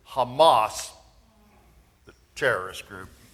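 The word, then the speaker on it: Hamas (חמס): Now, this is the Hebrew word for violence. That's pronounced Hamas.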